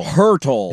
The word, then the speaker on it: Hertl: Heddle. – That was close. – Is that how you say it? – Hurdle. – Tomas Hurdle. – Hurtle. – Is there an R in there? Or is it just H-E-R-T-L.